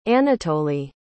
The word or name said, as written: Anatoliy